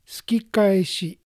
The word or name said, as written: Sukikaeshi